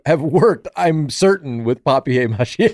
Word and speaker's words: papier mâché: Like It's papier French. Mâché. It's like a French word. – Yeah. – Papier mâché? – Paper mâché.